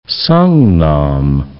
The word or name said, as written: Songnam